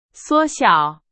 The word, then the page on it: 缩小: Chinese